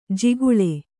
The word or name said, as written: ಜಿಗಳಿ